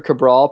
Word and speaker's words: Cabral: I don't think this is one, but Cesar Cabral pronunciation guide, last syllable, Brawl.